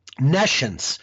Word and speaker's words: nescience: Nescience is N-E-S-C-I-E-N-C-E. Nescience.